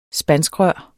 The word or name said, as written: spanskrør